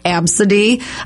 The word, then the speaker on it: ABCDE: The name ABCDE, pronounced Absidy. Lester, probably because it rhymes with, the name was ESPN, pronounced S-Pen.